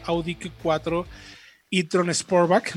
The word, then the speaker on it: Audi Q4 e-tron Sportback: Justo antes de irnos a música, estábamos platicando con Fred Chabot, quien estuvo en el lanzamiento de la nueva Audi Q4 e-tron Sportback.